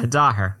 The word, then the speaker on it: Daher: Yeah, Dahir.